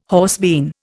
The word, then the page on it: horsebean: Indian